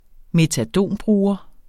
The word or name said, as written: metadonbruger